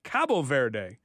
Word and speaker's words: Cabo Verde: Earlier today, Spain and Cap Verde, or if you want to use the Portuguese pronunciation, Cabo Verde, played to a scoreless draw.